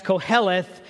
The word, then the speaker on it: Koheleth: It's a Hebrew word that is pronounced Koheleth, and it means a person who gathers or collects people.